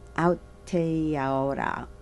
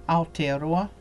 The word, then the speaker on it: Aotearoa: Easy to pronounce, Māori. All you need to know is the vowels, A-E-I-O-U. – Oku? – Yeah, uku. – Uku. – Meaning clay. Aotearoa.